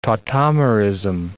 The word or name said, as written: tautomerism